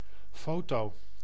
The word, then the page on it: photo: [la fɔ.to]; France